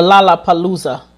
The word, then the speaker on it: Lollapalooza: And just matter of fact, he was taken off the I think he was supposed to perform at the Laugh-a-Palooza. Did I say that right? The Lollapalooza.